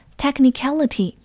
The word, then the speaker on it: technicality: Technicality 名词表示法律条款或者专业技术性，要注意它的发音，重音是在 cat 前面，所以读作 technicality。